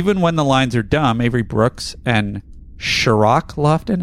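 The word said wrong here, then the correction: Cirroc: I've always pronounced it Sirik, Sirok, or Sirok. I've never had an SH sound on there.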